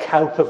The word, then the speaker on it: Cowper: I know it's spelt Cowper, but I can tell you it's Cowper.